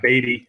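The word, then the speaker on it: Beaty: And like, just to clear this up, it is Beattie. I know it's spelled, it looks like Beattie if you're looking at it, but yeah, Beattie.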